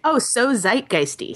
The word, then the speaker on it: Zeitgeisty: @SOZE, Zoegeisty? – So Zeitgeisty. – So Zeitgeisty. There it is.